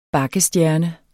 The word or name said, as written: bakkestjerne